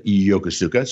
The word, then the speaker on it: Yokosuka: Yes, I was born while my dad was stationed over in Japan in Yokosuka, or as the Japanese pronounce it, Yokosuka.